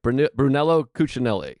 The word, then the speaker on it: Brunello Cucinelli: Brunello Cucinelli, I think. I could be pronouncing it wrong. – Sounds like a mob boss. – Brunello, C-U-C-U-N-E-L-L-I. Brunello Cucinelli.